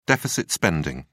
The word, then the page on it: deficit spending: British English